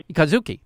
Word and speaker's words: Kazuki: Kazuki.